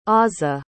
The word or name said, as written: aza